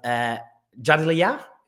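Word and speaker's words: Jadaliyya: He's the co-editor of Jadlia, is that right? Am I pronouncing it correct? Ish. You winced a bit there, I saw. So that was an ish pronunciation. – No, right on.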